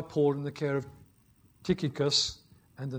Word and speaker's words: Tychicus: It's not pronounced Tychicus, it's Tychicus.